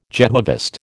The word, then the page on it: Jehovist: American English